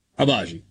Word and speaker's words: Agbaji: Agbaji? I just mispronounced it too, didn't I? – It's close. – You guys aren't going to bail me out, are you? – Agbaji nope. Works. – Agbaji, thank you.